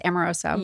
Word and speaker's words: Amoroso: Yes, Amoroso.